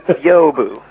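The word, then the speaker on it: Byobu: It's basically two syllables, where the first syllable is kind of one and a half syllables. – You don't know, do you? – Biobu. – Biobu. – Biobu. – Okay. – So the B-Y-O is Bio, and then B-U, Bu.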